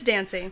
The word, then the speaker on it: Dancy: Am I saying his name right? Is it Darcy or no? It is Dan- Dancy. It's Dancy.